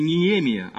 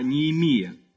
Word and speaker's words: Неемия: Кстати, для баптистов, которые баптюганы, вот очень такие серьезные, повторяю, не Неемия, а Неемия. Иеремия, Языкия и так далее. Ударение надо ставить правильно, как они произносились в оригинальном языке, то есть еврейском и арамейском языках.